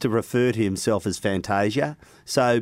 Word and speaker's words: Fantasia: Where are we at with the pronunciation of Orazio's surname? – I can tell you where we're at. No, I know where we're at. He is, I think, in Italia... It is Fantasia.